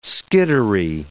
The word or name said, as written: skittery